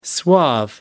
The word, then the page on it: suave: American English